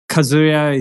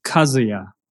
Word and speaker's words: Kazuya: I think Kazuya is fine, but I think the real pronounce is Kazuya, not Kazuya. – Like okay. Kazuya, maybe Kazuya. Yeah, that's okay. Japanese pronunciation.